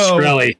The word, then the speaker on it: Shkreli: Just real quick, Martin Shkreli, is that how you pronounce his name? Pharma Bro. – Shkreli, yeah.